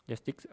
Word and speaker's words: Joysticks: Golden Justin Awards Joysticks, perdón, joystick, no me acuerdo cómo se pronuncia. Joysticks. Joysticks, ok.